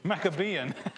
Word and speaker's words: Maccabean: As a matter of fact, it was these events that brought about the Macadamian. Did I say that right? Maccabean.